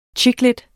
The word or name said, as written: chicklit